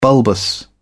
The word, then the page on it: bulbous: British English